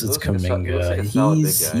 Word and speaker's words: Kuminga: I don't know how that's pronounced. – I like that pick for them. Yeah. It's Kuminga.